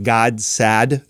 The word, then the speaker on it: Gad Saad: The way you pronounce his name, I would say it's like Gadsad. So G-A-D. – Gadsad. – Gadsad. I've heard people say it both ways. Gad. Yeah, so it's G-A. – I don't think he wants to call himself God. – Yeah, G-A-G-A. I think that's how you say it, though. G-A-D-S-A-A-D.